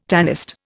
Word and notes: tanist: Indian

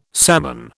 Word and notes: salmon: British English

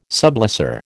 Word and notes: sublessor: American English

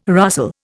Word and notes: rustle: Indian

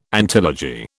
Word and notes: antilogy: British English

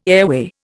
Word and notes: airway: Indian

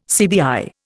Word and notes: CBI: Indian

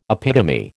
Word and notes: epitome: American English

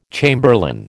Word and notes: chamberlain: American English

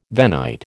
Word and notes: Venite: American English

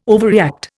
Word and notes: overreact: Indian

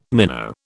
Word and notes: minnow: British English

